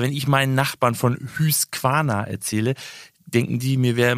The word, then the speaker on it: Husqvarna: Es ging um die Rasenmäher-Roboterfirma Husqvarna, die natürlich nicht Husqvarna ausgesprochen wird, denn, so schreibt uns Alex, die Konsonanten im Schwedischen werden in der Regel wie im Deutschen ausgesprochen. Die Vokale aber, die klingen etwas anders. So auch das U, das wird fast immer wie ein Ü ausgesprochen. Es heißt also Husqvarna.